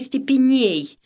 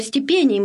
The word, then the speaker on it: степеней: По-русски бы мы ударение сделали псалмы степеней, но степеней мы будем говорить по-церковно-славянски.